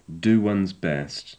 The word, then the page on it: do one's best: English